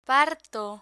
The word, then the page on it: parto: Spanish